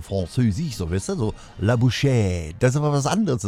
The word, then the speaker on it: La bouche: La bouche.